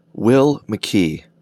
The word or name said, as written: Will McKee